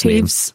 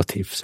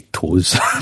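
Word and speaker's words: Toews: I think it was a guy called Jonathan, I'm going to butcher this Taves. – Name. Oh, Taves. Thanks for that, because I was going to say Toes, basically.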